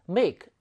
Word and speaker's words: make: make는 ing 형태 만들어줄 때 make, e가 발음되지 않죠.